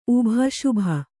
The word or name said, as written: ಶುಭ